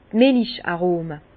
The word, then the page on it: d' Milich-Arome: Alsatian; Bas-Rhin; Herrlisheim